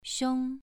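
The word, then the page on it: xiong: tone 2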